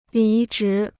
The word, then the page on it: 离职: lí zhí